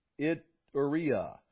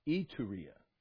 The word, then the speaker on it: Iturea: Tetrarch of Galilee and his brother Philip, tetrarch of the region of, now there you go, Iturea. Okay, close enough. No, it's Iturea. Iturea.